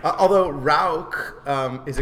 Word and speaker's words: Rauch: How do you say Bach in German? It's Bach. – Bach. Yeah. – Same one. – German. – It's Rauch.